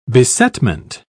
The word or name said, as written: besetment